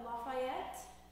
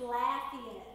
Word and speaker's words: Lafayette: And while there are some cultural differences and some word pronunciations that we're just not quite, I'm not quite 100%, I always want to call it Lafayette. Apparently it's Lafayette. That's how you have to pronounce it, apparently.